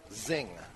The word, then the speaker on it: Xing: And the cigar is spelled X-I-N-G, but actually pronounced Zing. – Like xylophone is spelled with an X. – Okay. And sounds like a Z.